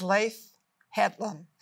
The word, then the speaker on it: Leif Hetland: And it is Leif Hetland.